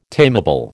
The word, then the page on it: tamable: American English